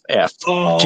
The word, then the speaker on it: Geoff: Even if his name is spelled G-E-O-F-F, Jeff Fox.